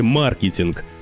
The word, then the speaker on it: маркетинг: Слово пришло к нам из английского с ударением на первый слог, то есть маркетинг. Но иноязычные слова не обязательно должны сохранять ударение языка источника. И верно в этом случае говорить и маркетинг, и маркетинг.